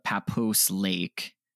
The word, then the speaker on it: Papoose Lake: papoose lake or papoose lake i don't i don't know i'm probably butchering that one star review he papoose it's Papoose Lake. Papoose.